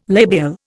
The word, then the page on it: labial: Indian